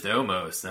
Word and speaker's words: Omos: But I don't remember an Amos. I can't say his name all of a sudden. – Yeah, it's just Omos. I mean, it's not that hard.